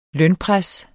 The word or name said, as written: lønpres